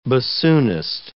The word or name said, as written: bassoonist